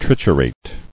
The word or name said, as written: Triturable